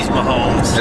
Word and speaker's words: Mahomes: It's Mahomes.